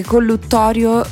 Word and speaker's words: collutorio: ed ho scoperto oggi che collutorio si scrive con una T, collutorio. – Cioè, questo grande mistero, perché insomma tantissimi. – Lo diciamo tutti con due T, mi confermate? – È vero, è vero.